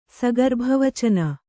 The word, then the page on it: ಸಗರ್ಭವಚನ: kannada